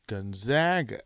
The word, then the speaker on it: Gonzaga: Y'all need to learn how to pronounce Gonzaga versus Gonzaga.